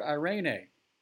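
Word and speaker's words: eirene: The word peace here is the word irene.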